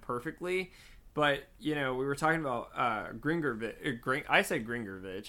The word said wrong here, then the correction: Grigorovich: How do you say it? – Grigorovich. – Grigorovich. There you go.